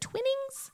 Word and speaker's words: Twinings: For beverages, they will have hot cocoa, chocolate peppermint shake featuring Twinings. Is it Twinings? – Twinings. – Okay, I knew I was saying that wrong.